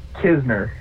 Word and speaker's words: Knizner: My name is Andrew Knizner.